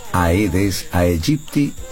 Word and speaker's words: Aedes aegypti: Se escribe Aedes aegypti, pero como en latín la A no suena, solamente desde la E, Aedes aegypti, y se lee Aedes aegypti.